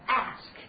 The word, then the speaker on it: ask: Ask，啊，也是以 A 开始，对不对？大家注意一下这个发音，对不对？英音和美音不大一样。那美音当中呢，发的是梅花音，我们发是 a，对不对？Ask，好，那么这个当然是问的意思了，这个比较简单，也有请求，比如说要某人做某事儿，对不对？Ask somebody to do something，啊，当然呢可以表示邀请的意思，对不对？